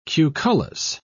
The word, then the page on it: cucullus: British English